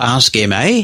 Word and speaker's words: Ask MA: Is it Ask Ma or Ask MA? How do you pronounce it? – We have internally called it Ask Ma. It's a short acronym for Ask Microsoft Accessibility.